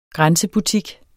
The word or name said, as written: grænsebutik